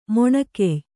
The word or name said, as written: ಮೊಣಕೆಯ್